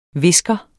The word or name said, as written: VISKEREN